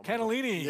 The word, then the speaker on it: cannellini: I can't even read it without cannellini.